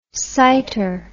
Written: sighter

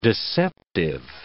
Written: deceptive

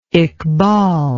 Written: iqbal

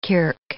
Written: kirk

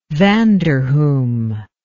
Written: vanderhum

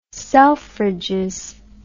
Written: Selfridges

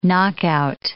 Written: knockout

